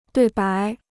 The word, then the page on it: 对白: duì bái